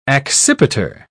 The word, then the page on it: accipiter: British English